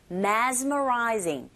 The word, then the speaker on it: mesmerizing: 특히 mess라기보단 z로 발음해 주셔야 돼요. mesmerizing,